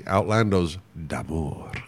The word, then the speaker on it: Outlandos d'Amour: So let's hear "Peanuts" from Outlandish D. Amore. Is that the Outlaws of Love? – Is yeah. that what that means? Yeah, but you don't need to pronounce the whole D. Outlandos d'amour.